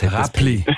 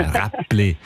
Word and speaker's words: Räppli: Du hast es mit einem B ausgesprochen. Und Räppli. – Ja, Räppli.